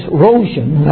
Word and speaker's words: rojin: The word is, and I don't speak Japanese, so if I butcher the pronunciation, I'm sorry to Japanese people. The word is rojin. That's how I would pronounce it. Is that, okay, good.